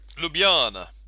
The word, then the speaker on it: Ljubljana: Ljubljana, I presume that's how it's pronounced. – Ljubljana, yep.